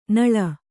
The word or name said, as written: ನಳ್ಳೆ